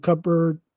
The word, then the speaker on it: cupboard: Downing gets permission from Outwood to open the cupboard. I cannot say that word now. – Cupboard. – With a dumbbell. – Stop trying to pronounce the P and the B. Just do cupboard.